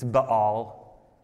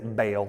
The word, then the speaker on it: Baal: It's not Baal. Like we're from the south, like Baal. It's Baal. That's what they were called, the Baals. We're called, but we're just going to say Baal.